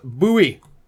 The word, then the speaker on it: Bowie: The true blue Bui What? Bowie. Bowie. Sorry. It's Bowie. Gonna take me a while. I've got 35 years of mispronouncing it.